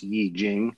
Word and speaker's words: I Ching: And I Ching is spelled I-C-H-I-N-G. I Ching, it looks like it's really pronounced Yi Jing.